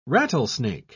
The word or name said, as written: rattlesnake